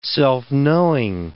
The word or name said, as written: self-knowing